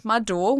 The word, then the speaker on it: Madeau: I share everything on my Instagram, which is at Madeu, one of my nicknames, which is spelled M-A-D-E-A-U.